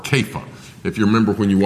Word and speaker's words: Kepha: And you would have heard when he's speaking to Peter, he calls him Kepha. That's how they would have pronounced that.